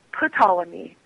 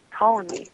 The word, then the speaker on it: Ptolemy: And I said, Ptolemy. And he shook his head no and he said, Ptolemy. And when I said it right, he nodded okay and he let go and let me take the book.